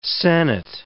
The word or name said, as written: sennit